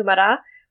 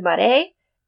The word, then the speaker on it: Marais: And a quick note on pronunciation I am pronouncing this Mara, which is the way um, the Acadians in Nova Scotia pronounce it. It's a, it's a dialectical French.